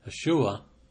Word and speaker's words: Yeshua: It's a contraction of the word Joshua, which in Hebrew is pronounced Yeshua, which was the Hebrew name of Jesus.